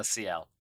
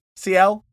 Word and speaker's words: Ciel: Uh, Ciel. – Ciel.